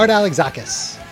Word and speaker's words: Alexakis: How would you pronounce his last name? – Art Alexakis.